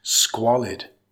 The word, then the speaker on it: squalid: Emphasize the first syllable, it's pronounced squalid.